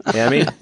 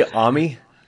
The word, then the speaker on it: AMI: AMI? You say AMI? – No, AMI. It's three syllables.